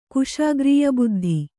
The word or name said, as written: ಕುಶಾಗ್ರೀಯಬುದ್ಧಿ